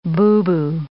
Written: boubou

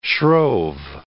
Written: shrove

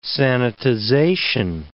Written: sanitization

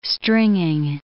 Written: stringing

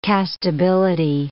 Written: castability

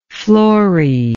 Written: flory

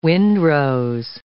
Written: wind rose

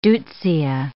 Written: deutzia

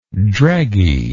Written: dregginess

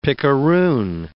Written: picaroon

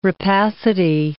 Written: rapacity

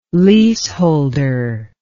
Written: leaseholder

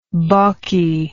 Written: balkiness

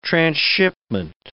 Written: transshipment

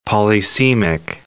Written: polysemic

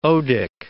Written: odic

